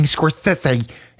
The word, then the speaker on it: Scorsese: And he was like, I've been saying Scorsese all my life. – Yeah, and Scorsese was just like, why does everybody think it's fucking Scorsese? It's Scorsese. – It's not even spelled like Scorsese. – Yeah, it's not. It's spelled at like all. Scorsese. Why are you doing this? – His big glasses on.